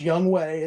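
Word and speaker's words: Younghoe Koo: And even though his name is pronounced Young Way, it's spelt Young Ho Koo.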